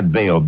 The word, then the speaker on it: Baio: Oh, it's Bayo. See, I'm pronouncing his name wrong. That's why you got confused. It's Scott Baio, B-A-I-O.